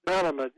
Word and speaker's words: Malamud: Is it Malamud? Am I pronouncing that correct? Malamud. Bernard Malamud.